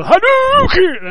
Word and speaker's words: Hadouken: No, you go Hadouken! That's the real way to say it. – Okay. – That's even how it's pronounced in the dictionary.